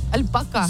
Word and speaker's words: альпака: Альпака на последнее А ударение.